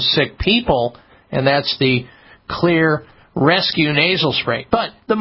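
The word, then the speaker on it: Xlear: I'm a big fan of using Clear nasal spray products. Clear is spelled with an X, X-L-E-A-R.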